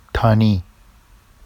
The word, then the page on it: tɑni: Vafsi